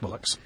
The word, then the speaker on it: Bolix: B-O-L-I-X, and pronounced Bollocks. So there we are. Not so much Sandra, but Bollocks.